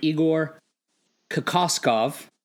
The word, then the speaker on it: Kokoskov: Came up with a nice little rundown, but then the Suns went out and today announced that they were hiring Igor Kokoskov.